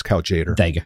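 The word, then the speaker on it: Tjader: Kaljadar, probably butchering that pronunciation. – Jader, I think is how it's typically pronounced. Kaljader.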